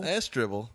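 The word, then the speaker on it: Asdrúbal: I'm a little worried about Azdrubal. – Oh, were we calling him Astrubal? – Astrubal. That's the proper pronunciation.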